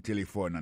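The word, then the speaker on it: telephone: Téléphone, c'est comme ça on dit téléphone en anglais. Nous devons répéter encore pour nous, téléphone. N'a pas remarqué que l'accent tonique l'a tombé sous premier syllabe là.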